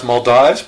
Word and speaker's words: Maldives: If I'm not mistaken, it's Maldives, possibly pronounced Maldives, but I've heard it both ways, so I'm going to call it the Maldives.